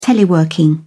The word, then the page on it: teleworking: British English